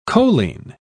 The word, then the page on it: choline: British English